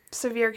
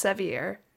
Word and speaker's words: Sevier: It looks like it's pronounced Sevier, but it's Sevier. I looked it up.